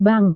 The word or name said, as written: bank